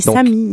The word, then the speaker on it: Samis: Les Samis.